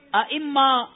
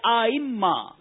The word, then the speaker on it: ائمہ: درست تلفظ کا انتخاب کریں, ائمہ یا آئمہ؟ – نمبر 2 آئمہ. – آپ نے کہا نمبر 2 آئمہ, آپ کا جواب بالکل غلط ہے.